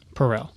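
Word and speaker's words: Perell: One of the things that I thought was interesting about this, David Perel, am I saying his name right? – Yeah, Perel. – Perel. Yeah, it's like Purell.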